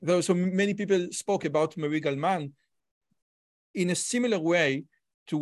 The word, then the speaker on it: Murray Gell-Mann: mention Murray Gell-Mann because maybe I pronounce his name uh, wrong, but regarding well, you, Murray Gell-Mann. Yeah.